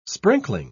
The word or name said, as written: sprinkling